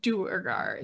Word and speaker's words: Duergar: The Forgotten Realms wiki, I checked the pronunciation guide just now, says Duergar is correct.